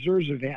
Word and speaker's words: Zurzuvae: Yeah, this has just come up because it was just recently approved, and they approved Zurzuvae, I believe is the pronunciation of it.